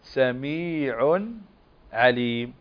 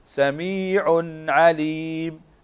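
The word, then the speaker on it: سميعٌ عليمٌ: اسمع، النون تنطق هنا سميع عليم، سميع عليم، سميع عليم. تفضل. – سميع عليم.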